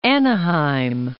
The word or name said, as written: Anaheim